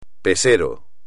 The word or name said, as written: pesero